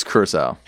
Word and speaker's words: Curaçao: Well, think about it like blue Curaçao, like the alcohol. – Thomas? – I'm not familiar. So. Okay. – It's Curaçao.